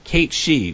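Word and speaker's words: Cat Sìth: Then there's the Cate She, which is actually written like Cat Sith. So if you want to, you know, look that up.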